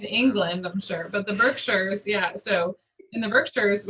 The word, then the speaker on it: Berkshires: That's how you say it, Berkshires? – Berkshires. – Oh, I just thought it was Berkshires.